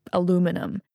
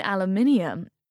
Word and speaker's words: aluminium: "Like aluminum." – "You mean aluminium?" – "Ah, there it is." – He cupped his ears in pleasure. – "That extra syllable. Aluminium.